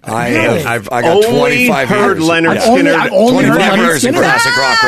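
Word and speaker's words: Lynyrd Skynyrd: Debut album is actually titled Leonard Skinner, pronounced Leonard Skinner. – Right. – But the crazy thing about it is it's spelled L-Y-N-Y-R-D, S-K-Y-N-Y-R-D. Y is the only vowel in both words, so why would they be pronounced differently? – I think they're stylized.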